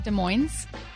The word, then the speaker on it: Des Moines: Well, no, but in Washington, isn't it Des Moines? – Yes, it's Des Moines.